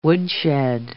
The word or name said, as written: woodshed